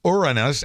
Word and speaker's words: Uranus: Among the scientific discoveries this week about the seventh planet from the sun, Uranus, as it is apparently correctly pronounced, has a lot of hydrogen sulfide in its atmosphere.